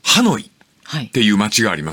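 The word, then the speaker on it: Hà Nội: 河内でいいんですか、それを、ベトナムの音ではハノイって読むんだって。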